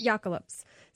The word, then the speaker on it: Jökulsá: The Jökulsá, I don't know if I'm saying that right. – Yeah, it's the Jökulsá. – Jökulsá.